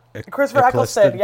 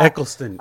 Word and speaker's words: Eccleston: Christopher Eccleston. Yeah. – Eccleston.